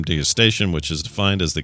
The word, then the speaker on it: degustation: It's a hard G, degustation menu.